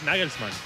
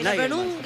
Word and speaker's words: Nagelsmann: ¿Cómo es el técnico de Alemania, Natalia? – Yo no lo pienso pronunciar. – Es Nagelsmann. – Claro, es Nagelsmann. – ¿Cómo suena? – Es Nagelsmann, pero yo consulté algunas páginas que...